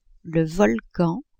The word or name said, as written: volcan